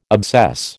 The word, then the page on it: obsess: American English